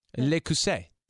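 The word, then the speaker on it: Le Creuset: But we also recently got how do you say that? Le Cousset. – Le Cousset. – Like I say, good job.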